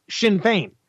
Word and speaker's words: Sinn Féin: All right, Sinn Féin, it's spelled S-I-N-N-F-E-I-N, but it's pronounced Sinn Féin.